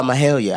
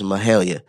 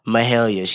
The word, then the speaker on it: Mahalia: If you could show me all the way around, That was Grateful by Mahalia. I said her name wrong. I'm sorry. It was Mahalia. Mahalia.